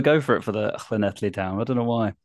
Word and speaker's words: Llanelli: It's pronounced Llanelli, if you want the actual Welsh version.